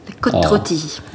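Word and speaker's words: Côte-Rôtie: Det låter alltid lite finare, alltså, när man tar det på ett väldigt franskt uttal. Côte-Rôti.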